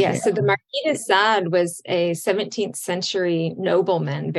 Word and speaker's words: Marquis de Sade: And thinking about fantasies and pleasure means Marquis de Sade was out there.